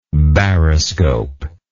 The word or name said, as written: baroscopical